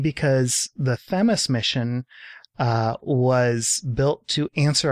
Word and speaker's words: Themis: It was the launch of Themis, not Themis. I actually found a video with, I think, the PI who pronounced it Themis.